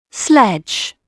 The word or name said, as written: sled